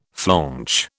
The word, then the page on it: flaunch: British English